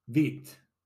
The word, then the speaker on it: wit: white is translated into wit in flemish albeit pronounced wheat.